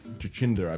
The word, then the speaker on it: Jacinda: Jacinda, I believe it's Jacinda. – Oh, sorry. – I thought it was a soft J.